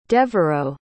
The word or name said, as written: devereaux